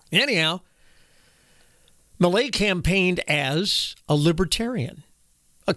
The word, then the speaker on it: Milei: Javier Milei. It's spelled M-I-L-E-I. Some circles, depending on which language you speak, it could be pronounced Javier Milei.